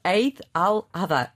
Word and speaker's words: Eid al-Adha: provavelmente terei, Eid al-Adha.